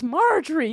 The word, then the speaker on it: Marjorie: So I called her Majori, and she yelled at me, and she was like, it's Marjorie, you can't read.